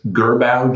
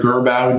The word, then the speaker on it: Girbaud: Gerbau jeans? – No. – Okay. Well, that's a French word too. – Gerbau? – G-I-R-B-A-U-D. How would you pronounce Gerbau.